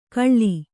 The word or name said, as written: ಕಳ್ಳಿ